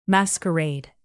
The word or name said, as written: masquerade